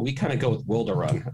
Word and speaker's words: Wilderun: when Wilderun came on, and I said, and we've got Evan from Wildrun.